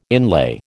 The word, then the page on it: inlay: American English